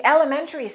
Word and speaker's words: elementary: In elementary schools, female teachers outnumber male teachers. Let's pronounce elementary. It's four syllables, not five. Elementary.